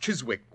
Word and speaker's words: Chiswick: Chiswick? – It was originally pronounced Chiswick, Watson, and it's still so spelled.